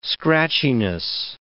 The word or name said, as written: scratchiness